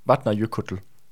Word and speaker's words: Vatnajökull: Vatnajökull.